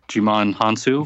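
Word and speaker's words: Djimon Hounsou: It's a crazy coincidence, and they both have Jimon Hansu, if I pronounced that correctly.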